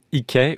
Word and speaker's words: Hickey: La musique de Sean Ikey, ou Ikey peut-être si je voulais le prononcer plutôt à l'américaine, voilà.